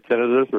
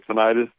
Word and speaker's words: tinnitus: And tinnitus, you can say tinnitus or tinnitus or the different pronunciations, tomato, tomato, which is ringing in the ears.